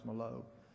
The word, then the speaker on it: Milot: Alfred Merlo, his name is spelled M-I-L-O-T, but I think it's pronounced Malo.